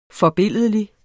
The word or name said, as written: forbilledlig